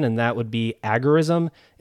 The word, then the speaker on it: agorism: And let's start off with pronunciation, because usually when I hear someone talking about agorism, I usually hear it pronounced agorism, and agorism kind of flows a little better, I think. But when the term was coined by Samuel Edward Konkin III, he termed it agorism, and that's how he pronounced it. And when you go back to the root word that agorism is based off of, that is agora, the pronunciation would signify that agora would be agorism.